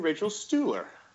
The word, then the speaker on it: Rachel Stuhler: And Rachel Stuhler.